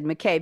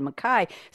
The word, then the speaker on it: Mackay: When I read the scene, Edwin, from the quote from Miss Jean Brodie, I said McKay because I was reading the way the English pronunciation, and you said Mackay.